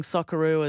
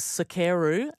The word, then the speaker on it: Socceroo: You might have seen as well, there was a short video online of one of the BBC presenters pronouncing Socceroo as Sokeru.